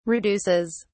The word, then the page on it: reduces: British English